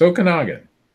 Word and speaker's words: Okanagan: It's Okanagan. It's Okanagan Lake.